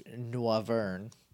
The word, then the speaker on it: Noivern: First of all, I'm going to evolve Noibat into Noivern. It's French, so you pronounce the O-I that way.